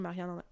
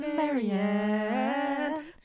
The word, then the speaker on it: Marianne: Je sais pas comment on va prononcer Marianne. Marianne? Marianne. – Marianne. Comme la chanson de Leonard Cohen. Marianne.